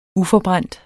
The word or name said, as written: uforbrændt